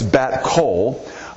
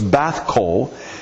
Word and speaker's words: Bath Kol: Now, it looks like it's Bat Kol, but it's actually pronounced B-A-T-H, so it's Bath Kol is how you would say that,